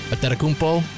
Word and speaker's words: Antetokounmpo: You go, Antetokounmpo, Antetokounmpo. Just not Antetokounmpo or anything of the sort.